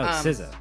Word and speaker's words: SZA: There's also a song called Drew Barrymore by SZA, or SZA, and I don't know how to pronounce it because I'm an asshole. – Oh, SZA.